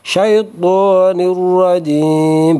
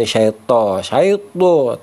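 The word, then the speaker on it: الشيطان الرجيم: A'udzu billahi من الشيطان syaitu syaitu syaitonir rajim bukan syaito syaito tapi syaito syaito nah ya.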